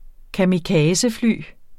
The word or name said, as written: kamikazefly